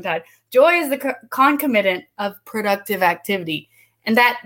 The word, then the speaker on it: concomitant: Joy is the concomit Concomitant. – Ah, I can't. – you got it. You got it. Concomitant.